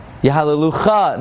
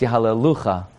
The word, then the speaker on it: Yahalelucha: Oid, Yahalelucha. No, it's Yahalelucha.